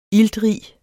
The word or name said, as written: iltrig